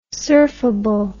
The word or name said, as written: surfable